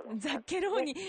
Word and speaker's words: ザッケローニ: 私もザッケローニって発音するんですか。 – そうです、ザッケローニ、ね、これ覚えるのもあの日本の皆さんも最初大変だったんですけれども。 – あ、そうなんですか。 – はい、ザッケローニ